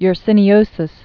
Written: yersiniosis

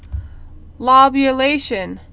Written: lobulation

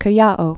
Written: Callao

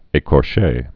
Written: ecorches

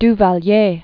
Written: Duvalier

